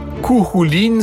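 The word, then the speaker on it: Cú Chulainn: Kouhouline, ça s'écrit Kouchoulaïn.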